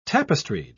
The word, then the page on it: tapestried: British English